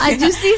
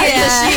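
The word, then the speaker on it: Ajoshi (아저씨): Ini ada aku bingung bacanya kayak opa atau ajoshi. – Ajoshi sih ya. – Ajoshi ya.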